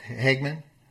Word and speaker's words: Hageman: Okay, so I am back, and we actually have Harriet Hageman, and I'm pronouncing the last name correct, Hageman? – Hageman.